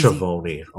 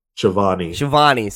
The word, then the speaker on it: Schiavone: Schiavone. – Sorry. I should be able to say it's Italian. I should be able to read it.